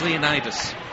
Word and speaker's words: Leonidas: I pronounced it King Leonidas. No, I didn't. Leonidas? Leodonis, I can pronounce it. It's Leonidas.